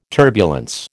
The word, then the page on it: turbulence: American English